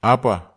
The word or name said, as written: apwa